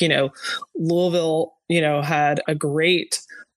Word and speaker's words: Louisville: Good pronunciation, by the way, of Louisville.